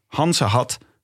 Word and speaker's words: Gansengat: Tijdens carnaval wordt Goes eigenlijk omgedoopt tot Hansehat... Eigenlijk Gansengat. Maar in Goes wordt de G uitgesproken als een H.